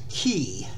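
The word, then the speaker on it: quay: Spell the word meaning wharf, which is pronounced key.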